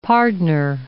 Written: pardner

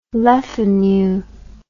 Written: le fanu

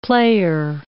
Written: player

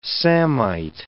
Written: samite

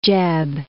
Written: Jebb

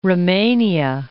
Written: Romania

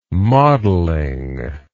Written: modeling

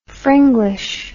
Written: fringlish

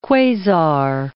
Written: quasar

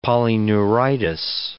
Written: polyneuritis

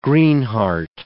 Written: greenheart

